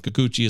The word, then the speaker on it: Kikuchi: You say Kikuchi. I say Kikuchi as well because that's how you say his name.